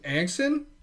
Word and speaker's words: Anxin: Then we had a girl named Anxin. I believe that's how you say it. It was like A-N-X-I-N, which is...